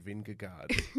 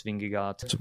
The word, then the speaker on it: Vingegaard: Even in prep, as a little mental reminder, I was saying salt and Vingegaard. Salt and Vingegaard, salt and Vingegaard. – Suffice to say, that's not how you say it.